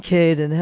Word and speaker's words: Caden: Sean Caden. – How do you spell that? – C-A-D-E-N.